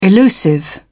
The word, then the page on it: elusive: British English